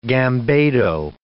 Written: gambado

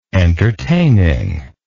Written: entertainingness

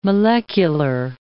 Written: molecular

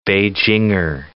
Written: Beijinger

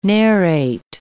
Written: narrate

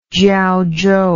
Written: Jiaozhou

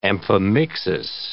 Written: amphimixis

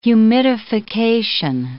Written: humidification

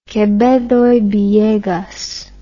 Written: Quevedo y Villegas

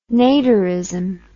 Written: Naderism

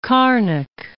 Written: Erskine of Carnock

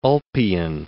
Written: Ulpian

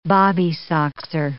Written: bobby-soxer

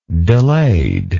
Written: delayed

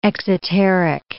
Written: exoteric

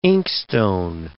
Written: inkstone

